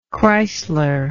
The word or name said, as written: chrysler